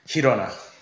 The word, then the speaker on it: Girona: Girona, am I saying that right? – Girona. – Girona. – There you go.